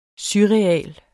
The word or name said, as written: surreal